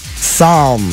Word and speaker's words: Psalm: Psalm. – Psalm? – With a P? Silent P, silent L. Psalm. – Wait, P, silent L would be Sam. – No, but they said it's pronounced Psalm.